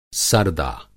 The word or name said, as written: सर्दा